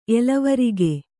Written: ಎಲವರಿಗೆ

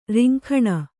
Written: ರಿಂಗಣ

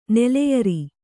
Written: ನೆಲೆಯರಿ

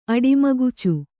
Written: ಅಡಿಮಗುಚು

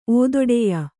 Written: ಓದೊಡೆಯ

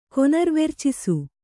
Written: ಕೊನರ್ವೆರ್ಚಿಸು

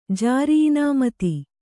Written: ಜಾರಿಇನಾಮತಿ

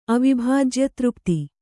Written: ಅವಿಭಾಜ್ಯತೃಪ್ತಿ

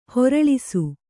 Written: ಹೊರಳಿಸು

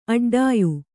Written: ಅಡ್ಡಾಯು

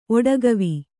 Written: ಒಡಗವಿ

